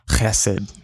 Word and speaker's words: chesed: You actually have to clear your throat for this. It's chesed. That's how you say it. chesed.